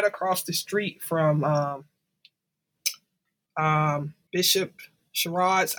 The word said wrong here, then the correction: Sheard: I never say his name, she, Bishop Sheard, uh, you know, his church.